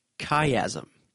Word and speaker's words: chiasm: C-H-I-A-S-M. Some people like to pronounce it chiasm. I say chiasm, but that's a whole other thing too.